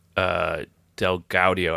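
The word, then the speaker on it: DelGaudio: which is Derek Del Gaudio, I believe is how his last name is pronounced, his show called In and of Itself. – Correct.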